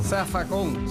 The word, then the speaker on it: Zafacón: Zafacón, se escribe con Z, pero se dice con S en puertorriqueño.